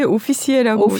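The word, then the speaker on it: officier: 뭐 다들 잘 아시겠지만 2019년 프랑스 문예 공로 훈장 오피씨에 뭐라고 발음해요? 이거 진짜 원어 발음은 잘하셨는데요. – 네, 오, 예, 오피시에라고. – 오피시에, 네 네네.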